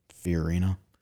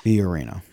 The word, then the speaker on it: Fiorina: I can never pronounce her last name. Carly, whatever her name with HP, ran for president. – Fiorina. – Yeah, Purina, the dog for show? – Sure, yeah. – Purina, sorry. – Fiorina.